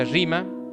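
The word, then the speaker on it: rima (ῥῆμα): Después también dice, gustaron la buena palabra de Dios, y acá el término para palabra es rema, se pronuncia rima, y se refiere a la palabra enseñada.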